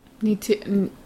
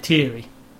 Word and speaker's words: Neytiri: How do you say it? – Neytiri, Ni- isn't it? Neytiri. – Yeah. yeah.